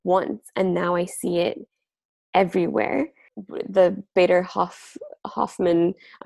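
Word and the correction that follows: Baader-Meinhof: I'm completely mispronouncing it, but Bader Meinhof phenomenon, you know, when you see that one thing and then you see it everywhere.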